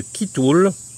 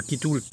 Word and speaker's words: kitul: Et puis on termine avec ce sucre du Sri Lanka, du sud de l'Inde, qui s'appelle la sève de Kitoul, de Kitoul K-I-T-U-L, ça se prononce comme ça,